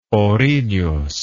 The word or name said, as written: ourinhos